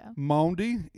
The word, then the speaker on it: Maundy: Am I saying that right? – I think so. – Maundy, M-A-U-N-D-Y, Maundy Thursday.